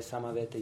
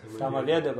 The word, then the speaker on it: Самавета: Большая часть преданных произносит это как? – Самаведа Бхакта Вринда Киджай. То есть Киджая Бхакты Самаведы.